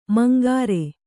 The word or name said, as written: ಮಂಗರೆ